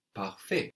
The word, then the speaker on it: parfait: And we said that the fact between perfect is related to fair, and perfect in French is parfait, parfait. Parfait. P A R F A I T. And of course, that T on the end is silent.